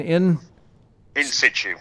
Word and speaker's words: in situ: In situ.